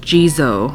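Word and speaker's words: Jizō: Jizu statues, believed to provide safety and protection to children. In Japan, oh, it's pronounced Jizo. I'm stupid.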